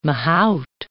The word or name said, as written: mahout